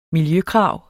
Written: miljøkrav

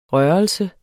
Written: rørelse

